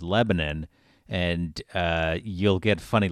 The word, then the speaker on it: Lebanon: But people from out of state always think we're saying it wrong because the country is pronounced Lebanon, right? – Right.